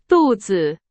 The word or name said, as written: 肚子